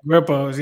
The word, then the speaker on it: Grippos: like Grippos. Ain't that how you say it? – Yeah, Grippos. Yeah, you got to get you some Grippos.